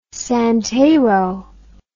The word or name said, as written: santero